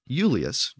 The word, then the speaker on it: Iulius: It was actually pronounced Julius during the period of classical Latin, and again, it was spelled I-U-L-I-U-S.